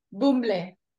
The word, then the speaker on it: Bumble: Bumble.